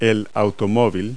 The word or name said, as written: car